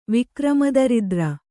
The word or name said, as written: ವಿಕ್ರಮದರಿದ್ರ